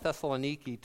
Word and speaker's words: Thessaloniki: And uh, it's interesting that Paul came to Thessalonica, Thessalonica, and they call it Thessaloniki today, I believe is the way they pronounce it when we were there.